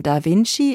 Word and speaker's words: Da Vinci: Die Aussprache von Da Vinci ist Da Vinci.